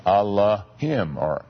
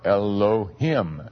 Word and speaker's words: Elohim: is Allahim, or Elohim. We can pronounce it either way.